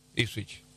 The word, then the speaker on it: Ipswich: Yo le dije del, y lo quiero decir bien, el Ipswich. – Sí, ¿se dice así? – Sí, Ipswich.